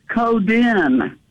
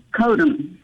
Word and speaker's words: Coden: He pronounced it Coden, not Codem.